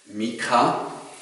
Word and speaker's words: Micha: Liebe Gäste, liebe Gemeinde, Micha, so ist die hebräische Aussprache, hat vor 2750 Jahren gelebt.